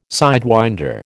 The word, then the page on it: sidewinder: American English